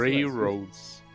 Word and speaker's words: Railroads: Acho que é assim que se pronuncia. – Railroads. – Muito bem.